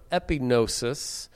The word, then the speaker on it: epignosis: It's pronounced epignosis.